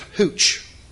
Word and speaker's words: Ruth: Her name is Hooch. It's Hooch. I was like, well, that's interesting. In English, her name is Ruth. It's spelled with a R-U-T-H. But in Portuguese, the R makes an H sound.